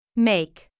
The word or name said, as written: make